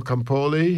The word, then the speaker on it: Campoli: And after that, Alfredo Campoli, who I should pronounce in English because he was an Englishman, although he had an Italian name and his parents were Italian.